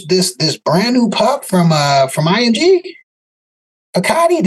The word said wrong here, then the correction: Picciotti: did I say his name correctly? – P- Picciotti. – Yeah. But you said it better.